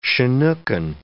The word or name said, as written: Chinookan